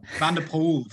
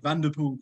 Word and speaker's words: van der Poel: Yeah, probably yes, pronounced that wrong, Simon. – Yeah. – Van Poel. – Yeah. – But he, Vanderpool. I'm remembering Ned Bolting saying it. Vanderpool.